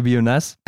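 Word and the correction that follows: Bionaz: Oder Bionar wird da ja ausgesprochen.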